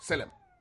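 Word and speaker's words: tselem: So the word selem, image is the word selem. Let me spell that for you. T-S-E-L-E-M. T-S-E-L-E-M. T-S-E-L-E-M. Selem.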